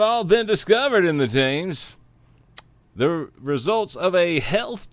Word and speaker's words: Thames: It's spelled Thames, but I believe it's pronounced Thames.